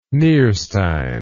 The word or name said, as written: Nierstein